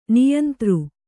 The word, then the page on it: ನಿಯಂತೃ: niyantř